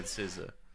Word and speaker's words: SZA: People smarter and more knowledgeable than me have also said SZA.